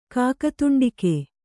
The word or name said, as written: ಕಾಕತುಂಡಿಕೆ